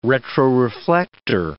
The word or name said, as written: retroreflector